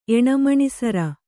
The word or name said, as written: ಎಣಮಣಿಸರ